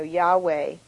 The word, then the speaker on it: Yahweh: So there's no J in Hebrew, though. And so, you know, Yahweh is a better way to really pronounce it.